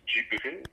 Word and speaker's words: Djibril: Il s'agit de Djibril, pour le dessin, comme ça se prononce, Djibril, et Yasmine, qui, elle, écrit donc sur sa mère, sa grand-mère et sur elle-même.